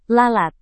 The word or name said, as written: lalat